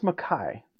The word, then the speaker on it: Micah: I also think we've talked a lot about, like, running back tandems and running back rooms in the offseason, and is it Micah or Mekhi Bernard? – It's pronounced Mekhi.